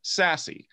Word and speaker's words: SASE: Secure Access Service Edge, pronounced SASE.